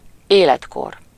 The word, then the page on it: age: American English